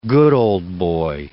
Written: good ol' boy

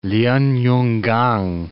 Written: Lien-yun-kang

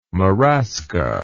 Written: marasca

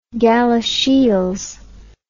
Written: Galashiels

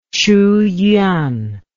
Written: Qu Yuan

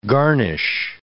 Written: garnish

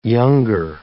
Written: younger

